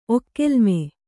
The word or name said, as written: ಒಕ್ಕೆಲ್ಮೆ